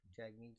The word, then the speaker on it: Jagmeet: Jagmeet Singh, or Jugmeet, how do you say it? – Jagmeet Singh. – Is it Jagmeet? I thought it was Jugmeet.